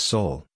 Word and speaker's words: Sowell: Pit that against the videos I watched yesterday, including Thomas Sowell, Common Sense in a Senseless World. His name pronounced like the word soul.